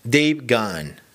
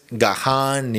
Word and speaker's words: Gahan: con la segunda canción, la segunda y última canción que es de Dave Gunn, que es, a propósito, es la pronunciación correcta, Dave Gunn. No sé si me sale igual, pero no es Gahan ni Gahan, es Dave Gunn, así le pronuncia él, así pronuncia él su nombre, que es el vocalista de Depeche Mode, ya les dije, y la canción se llama So Something de Dave Gunn.